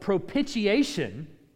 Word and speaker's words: propitiation: In the Old Testament, the idea of propitiation, I know you're going to ask, how do you spell that? P-R-O-P-I-T, so it's pro, pit, I, A, tion. Propitiation.